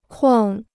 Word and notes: 框: kuàng

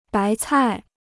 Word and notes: 白菜: bái cài

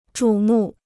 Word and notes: 瞩目: zhǔ mù

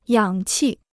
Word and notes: 氧气: yǎng qì

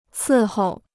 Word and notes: 伺候: cì hòu

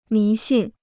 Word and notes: 迷信: mí xìn

